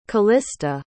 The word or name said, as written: callista